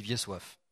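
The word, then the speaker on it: Wiesław: Ben, ça s'écrit Vieslo, mais en polonais, ça se dit Vieille Soif.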